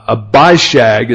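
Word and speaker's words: Abishag: a bishag is the way the expert tells us uh, we're supposed to pronounce this.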